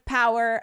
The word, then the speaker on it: POWHR: And we have actually partnered with Power. That's spelled P-O-W-H-R, which stands for Protect Our Water, Heritage, and Rights.